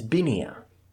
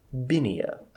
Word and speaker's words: Binia: My name is Binia. – Binia? – Yes, Binia. – That's a lovely name. – It's spelled B-I-N-I-A for the good player characters that are taking notes.